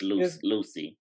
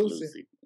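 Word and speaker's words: Lusia: It's Lucy. It's Lucy. Spelled Lucia, but it's okay, pronounced Lucy.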